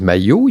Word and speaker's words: Mailhot: Mon nom s'appelle M-A-I-L-H-O-T. Il y a des gens qui disent maillot, il y a des gens qui disent Mayotte.